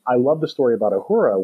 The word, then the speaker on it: Uhura: Uhura, is that how you say it? – Uhura and Solo.